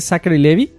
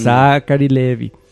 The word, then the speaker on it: Zachary Levi: Zachary Levi. Ahora sí lo dije bien, para que luego no me anden linchando en la semana. – Humillando. – Zachary Levi.